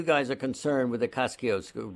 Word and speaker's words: Kosciuszko: Because we are one state, and because that bridge, Kosciuszko, as the Polish ambassador told me, it's pronounced Kosciuszko.